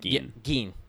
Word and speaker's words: Gein: G-E-I-N, I think is how you say that. Gein.